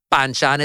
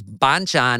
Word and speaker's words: banchan: It's not banchan, it's banchan. And it's not tlayuda, it's tlayuda.